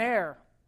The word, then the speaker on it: heir: I used to think that was called aner, but I just don't think that sounds quite right. So it's an heir, all right, is how you pronounce that, in case you were wondering.